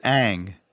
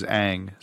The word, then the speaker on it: Ng: She pronounces it Ang. The last name is NG. Mm. So I apparently there are multiple ways to pronounce her preferred pronunciation is Ang.